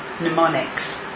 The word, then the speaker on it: mnemonics: They're in effect mnemonics. Did I say that correctly? – Yeah. – Okay, good. Mnemonics.